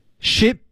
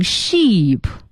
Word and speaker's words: ship: Ship to Kenya. – Ship to Kenya. – Ship to Kenya. – Ship to Kenya. – Ship to Kenya. – Ship to Kenya. – 嗯，千万不要读成 sheep，错了啊。